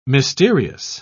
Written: mysterious

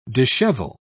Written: dishevel

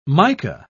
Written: mica

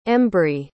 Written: embry

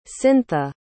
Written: cyntha

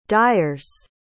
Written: dyers

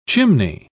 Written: chimney

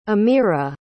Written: ameera

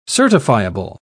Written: certifiable

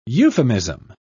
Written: euphemism

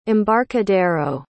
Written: embarcadero

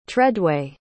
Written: tredway

